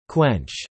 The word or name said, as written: quench